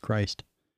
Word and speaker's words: Cryst: So it's spelled C-R-Y-S-T, but Wikipedia says pronounced Christ.